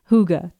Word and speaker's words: hygge: It's spelled H-Y-G-G-E, but you say it hygge. Hygge, okay?